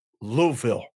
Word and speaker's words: Louisville: We pronounce it Louisville. – Louisville. – Like lullaby. – That's what I said. Louisville. – Louisville. – Louisville. Yeah. – Two syllables. I know. Louisville. – Yeah. You got to mumble it. Louisville. – Two syllables. Louisville. There you go. That's how you say it.